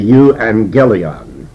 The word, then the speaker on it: euangelion: But in Greek, when two G's come together, the first G actually becomes an N, and we say euangelion. EU is a prefix. And this prefix means good. A-N-G-E-L, that's actually the word angel.